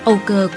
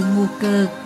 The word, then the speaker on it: Âu Cơ: Ngu cơ chính là Âu cơ, Người Việt gọi Âu Cơ, Quảng Đông và Mường phát âm như Ngô Cơ.